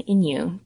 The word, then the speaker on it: Inuk: And a single Inuit person is an Inuk, two syllables.